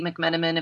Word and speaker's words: McMenamin: I'm also at Twitter at Cindy McMenamin, if you can spell that last name.